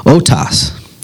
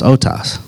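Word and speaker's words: otos (αὐτός): In the Greek language, the Greek word for this is otos. It's spelled auto with an S on the end, but it's pronounced otos.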